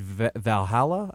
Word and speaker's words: Valhalla: But yeah, he'll be back at the PGA at Valhalla, I think is how it's pronounced. I'll have to get our guy Chris to confirm to me the pronunciation there. But that's you got that right.